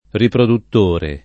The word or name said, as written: riproduttore